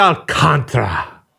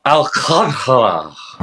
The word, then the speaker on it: Alcantara: Sandy Alcantara. – Alcantara. – Alcantara.